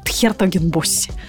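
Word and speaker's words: 's-Hertogenbosch: Это на самом деле пожары, которые происходили в Хер Хертогенбоссе. Никак не могу его научиться называть с ударением на первый слог.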